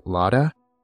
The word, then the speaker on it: Laura: And the other roommate, I think it's pronounced like Lada, is how Amanda says it, but I think it's like spelt like Laura.